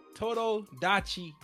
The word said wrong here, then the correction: Tomodachi: Game. – Tomo. There we go. I don't know where you saw that D at.